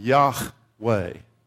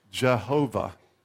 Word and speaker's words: Yahweh: Yahweh. But that is also, if it's vowelized differently, can be pronounced Jehovah.